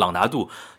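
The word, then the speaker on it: Ronaldo: 呃，一个什么朗拿度、朗拿电奴那那个，我老是看球辰光朗拿度，就罗纳尔多嘛，叫朗拿度，贝克汉姆叫避嫌，是按照广东闲话发音来的。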